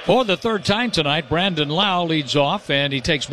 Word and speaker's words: Lowe: Spells his name L-O-W-E, pronounces it Lau.